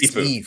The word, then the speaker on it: Sifu: It's just Sifu. Sifu. Yeah.